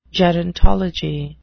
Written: gerontology

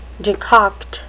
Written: decoct

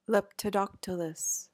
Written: leptodactylous

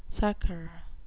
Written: succor

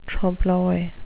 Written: trompe l'oeil

